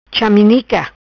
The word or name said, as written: Chaminica